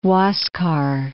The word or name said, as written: Huascar